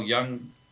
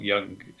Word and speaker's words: Jung: And Carl Jung, spelled J-U-N-G, not young in the other pronunciation of the word.